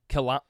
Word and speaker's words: Kelowna: Kelowna. – How do you say it? – Kelowna.